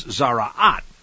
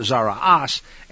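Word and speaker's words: tzara'at: And depending upon whether you have the Sephardic or the Ashkenazi pronunciation, sometimes you'll see it written Zaraas, because they like to say Shabbos instead of Shabbat and so forth. But anyway, it's Zaraat or Zaraas, and it's being described in here as something which, well, it's quite interesting.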